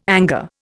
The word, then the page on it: anger: Indian